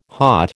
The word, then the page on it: hot: American English